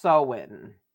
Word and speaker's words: Samhain: And then the fall equinox is pronounced, or is spelled M-A-B-O-N, Mabon. And then there's ones in between each of them. The word I'm very familiar with is Samhain, S-A-M-H-I-N. – Which I think is actually pronounced Sowin.